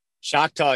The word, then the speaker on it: Choctaw: At, I don't want to butcher this, is that Choctaw Stadium? – Choctaw, yeah.